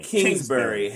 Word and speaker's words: Kingsbury: Why are sports news outlets saying that Cliff Kingsbury, that's his name, Kingsbury. Am I saying his name right? – Kingsbury.